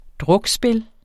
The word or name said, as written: drukspil